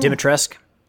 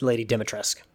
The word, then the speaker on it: Dimitrescu: Resident Evil Village Mercenaries mode will soon let you play as Lady Dimitrescu. – Dimitrescu. – If that's how you want to say it. – That's how it's said. Or at least according to one of my friends who plays play that game, it's Lady Dimitrescu.